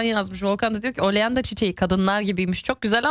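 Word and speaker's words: Oleander: Almancası nedir demiştim, tam telaffuzunu düzeltin lütfen demiştim. Gonca yazmış, diyor ki oleanda diye okunuyor dedi.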